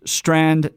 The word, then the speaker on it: Strachan: His last name sounds like it's different than it's spelled. Okay. So it's spelled S T R A. C H A N. Owen S T R A C H A N. It's pronounced Strand. It's a Scottish pronunciation, but he's the provost and research professor of theology at Grace Bible Theological Seminary.